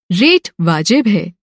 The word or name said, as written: रेट